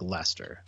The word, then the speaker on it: Leicester: First thing you got to do is know how to pronounce it, because if you say Leicester City, you're going to get laughed at. Just think of the guy's name like Lester, like John Lester.